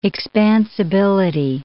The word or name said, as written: expansibility